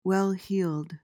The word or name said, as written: well-heeled